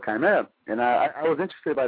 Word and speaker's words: Chimaera: So first of all, it's pronounced Chimaera. You say it right, but a lot of people, when they say it, they say Chimaera or whatever.